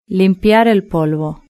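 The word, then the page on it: limpiar el polvo: Spanish (Spain)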